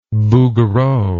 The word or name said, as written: Bouguereau